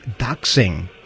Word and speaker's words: doxing: Okay, so anyway, the bulletin says law enforcement at risk for harassment and identity theft through doxing. Did I pronounce that right? Doxing. – Yeah. – D-O-X-I-N-G.